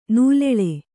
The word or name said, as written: ನಲ್ಲಳು